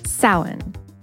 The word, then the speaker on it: Samhain: Say it with us Samhain.